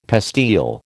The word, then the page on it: pastille: American English